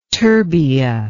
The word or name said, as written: terbia